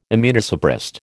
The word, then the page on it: immunosuppressed: American English